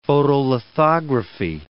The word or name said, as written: photolithography